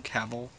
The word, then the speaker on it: Cavill: And Justice League, how Warner Brothers and Paramount are having a little bit of an argument over, like, not sure if I pronounce his last name right, but Henry Cavill, the guy who plays Superman. – Oh, Cavill.